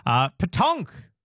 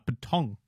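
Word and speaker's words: pétanque: I think I'm actually still hitting those K sounds a bit too hard. Petonk. As Linda taught us, you've got to swallow the sound at the end, as per the French.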